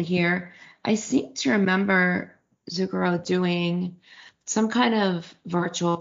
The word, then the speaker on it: Zucchero: Bono's going to be on Zucchero. Is that how you pronounce his name? – Yes.